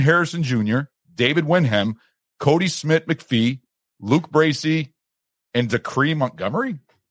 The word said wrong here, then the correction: Dacre: Hopefully I'm saying that right. – I think that's Dacre.